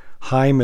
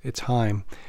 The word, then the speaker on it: Chaim: Heim is his name. It's spelled Chaim, but it's Heim.